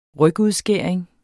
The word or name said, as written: rygudskæring